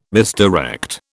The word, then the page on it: misdirect: British English